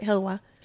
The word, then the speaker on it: halawa: There was, I'll tell you right now, I knew that the halawa, am I saying it wrong? – No, that's right, hilawa.